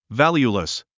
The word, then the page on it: valueless: vǽljulis; バリュウレス